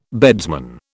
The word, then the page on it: beadsman: British English